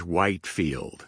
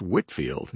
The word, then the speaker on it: Whitefield: Well, Pastor Greg, many people, if they saw George Whitefield's name written on a piece of paper, they'd probably pronounce it George Whitefield. Hmm. You know, the name's just not all that familiar to them, not familiar enough to know that it's Whitefield.